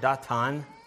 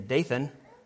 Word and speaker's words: Dathan: Korah, most of you who are familiar with the German would recognize the word Datan, is how they say it in German. But in English, I think they say Dathan.